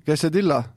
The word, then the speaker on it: quesadilla: Ja pearoog siis, kuidas hääldada? Kas julged ise? – Quesadilla. – Kas oli õigesti? – Quesadilla. – Quesadilla. – Ah, quesadilla.